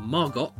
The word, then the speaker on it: Margot: To the actress Jean Harlow, who repeatedly mispronounced her name Margot. – "No, no, Jean, the T is silent, as in Harlow."